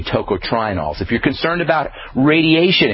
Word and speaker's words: tocotrienols: And by the way, tocotrienols is spelled T-O-C-O-T-R-I-E-N-O-L-S, tocotrienols.